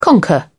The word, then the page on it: conquer: British English